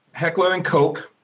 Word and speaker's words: Heckler & Koch: So German gun maker Heckler & Koch,